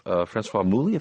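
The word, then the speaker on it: Françoise Mouly: These comics are entitled, and you co-edit them with your wife, Francoise Mouly, if I pronounce that correctly.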